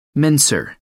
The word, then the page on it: mincer: American English